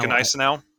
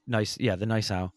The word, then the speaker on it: Gneisenau: Right now, Gneisenau. – Yes. See, the Gneisenau.